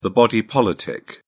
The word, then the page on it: body politic: British English